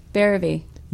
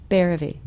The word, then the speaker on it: Bhairavi: Bhairavi. Bhairavi. Rhymes with therapy.